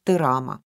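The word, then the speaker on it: тырама (드라마): Корейское слово созвучно японскому и произносится «тырама».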